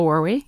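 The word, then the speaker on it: Werwie: And then you've got the screenwriter, Michael Weary. Am I saying that right? – Yeah, Michael Weary.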